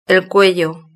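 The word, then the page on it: el cuello: European Spanish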